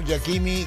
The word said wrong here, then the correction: Hakimi: Diop. – Hakimi. – ¿Eh? – Hakimi. – ¿Dónde es que yo dije Hakimi? – No, Hakimi. Se escribe con H.